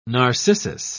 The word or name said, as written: narcissus